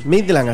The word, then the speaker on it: Midland: ¿Esta cómo se pronuncia? Midland, así se llama. Sí. – Midland. – Sí, señor.